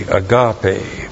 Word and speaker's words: agape: A-G-A-P, long E, agape.